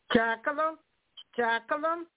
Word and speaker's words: Jacqueline: My name is Jacqueline Coba, and I'm calling from Louisiana. – Say your name again. I'm sorry. – Jacqueline. – Oh, Jacqueline. – J-A-C-Q-U-E-L-I-N-E.